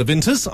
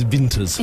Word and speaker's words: Taylor Vinters: Okay, and in the studio we've got Annabel Hayward from Taylor Vinters. I do pronounce it right. It is Vinters.